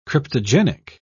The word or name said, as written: cryptogenic